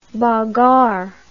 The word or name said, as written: bagarre